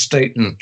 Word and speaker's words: Staton: Is it Staten or Staten? It's Staten, long A.